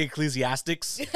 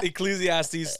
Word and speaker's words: Ecclesiastes: Oh, Ecclesiastes. – I always say Ecclesiastics.